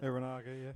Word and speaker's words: Uranage: Cena then turns into Bray Wyatt, hits him with a Uranage. Is that how you pronounce it? Uranage, yeah.